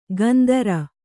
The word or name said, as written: ಗಂದರ